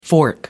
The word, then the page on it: fork: American English